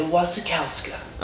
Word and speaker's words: Wasikowska: There was a film that I saw at Sundance called Damsel, which is starring Mia Oh my God, what is Wasikowska. – Wasikowska. Are you even saying that correctly? – I think so. I've heard it in a trailer. Mia Wasikowska.